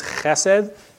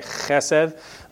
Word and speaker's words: Chesed: So in Hebrew you have basically a sort of a soft C sound and a hard C sound. And our fancy word, this covenant faithful word, is Chesed. Get that nice fricative in there, right? Chesed. This isn't that.